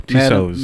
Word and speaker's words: Tussauds: So Tussauds.